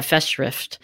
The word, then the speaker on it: Festschrift: Angela and Stephen mentioned this thing that I'd never heard before called Festschrift. Is that how it's pronounced? – Yeah, well, it's German, so I'm sure I'm butchering it. But yeah, Festschrift.